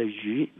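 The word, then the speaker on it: yu (鱼): Fish in pronunciation in Chinese called yu means surplus.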